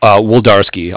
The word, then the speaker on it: Wlodarski: Woldarski.